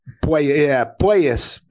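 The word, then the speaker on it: Puelles: I know this, the Saudi, about the pronunciation of his name, because I think it might be Pueyas. Pueyas, not Pueyas. – Yeah, so it is more of a poi than a poo. You're right. Claudio Pueyas.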